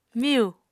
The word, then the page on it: miyu: Apatani